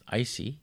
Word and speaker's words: ICI: It's spelled I-C-I, which can be either pronounced icy or in French, ici.